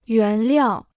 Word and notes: 原料: yuán liào

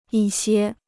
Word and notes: 一些: yī xiē

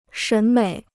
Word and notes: 审美: shěn měi

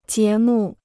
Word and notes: 节目: jié mù

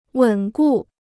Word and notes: 稳固: wěn gù

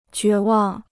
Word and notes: 绝望: jué wàng

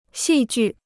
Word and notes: 戏剧: xì jù

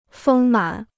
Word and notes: 丰满: fēng mǎn

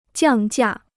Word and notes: 降价: jiàng jià

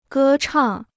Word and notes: 歌唱: gē chàng

